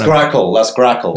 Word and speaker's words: Grakn: And also, I noticed on the website you talk about, is it GraphQL? Am I pronouncing that right? – And if you so that's Grakl. – Grakl, yes.